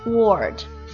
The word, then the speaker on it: thwart: 这个单词呢，上期节目当中我们遇到过，叫做 thwart，发音也比较怪啊，先是咬一下舌头。然后发一个 word thwart，希望大家能够掌握这个单词。